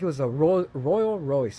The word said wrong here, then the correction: Rolls Royce: Royal Royce. I can't say it. How do you say it? – I know what you mean. – Yeah. How do you say it again? – Rolls Royce. – Yes, that.